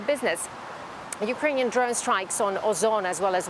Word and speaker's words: Ozon: What's the significance of, you know, companies that are being attacked like Wildberries and now Ozon? – Yeah, that's right. So you said it right.